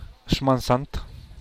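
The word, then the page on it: xman sant: Teotitlán del Valle Zapotec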